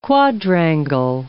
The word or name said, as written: quadrangle